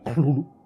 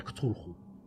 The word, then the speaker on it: Cthulhu: tenía dos maneras de pronunciarlo. Cuando se ponía en plan culto, que decía que la pronunciación evidente era Clulu. – Ah, a ver, ¿podemos repetirlo todo? – Clulu. y después él lo llamaba, algo, decía algo parecido a Cthulhu, Cthulhu, porque la TH en inglés sería con C.